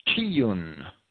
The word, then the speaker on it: Chiun: The Hebrew of Amos 5:26, rather than Rampha or Remphan, has Kiyun. It's spelled in English C-H-I-U-N.